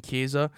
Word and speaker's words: Kiesza: Yeah, but it's not spelled like Kesa. – How's it spelled? – K-I-E-S-Z-A.